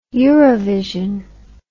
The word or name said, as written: eurovision